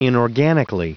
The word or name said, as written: inorganically